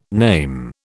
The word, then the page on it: name: British English